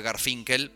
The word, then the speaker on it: Garfunkel: En este caso se trata de su versión de un tema de el reconocido y depresivo por momentos dúo Simon and Garfunkel, o hay quien dice que se pronuncia Garfinkel, pero nosotros le vamos a decir Garfunkel porque así nos suena mejor.